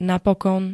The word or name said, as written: napokon